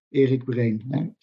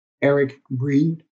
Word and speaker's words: Erik Breen: So if you if you ask me who are you, I I am Erik Erik Brain, as we say in Dutch. I'm um, from the Netherlands, so that's how I pronounce my name. But internationally, it's uh, Erik Breen, uh, uh, as it's often being pronounced.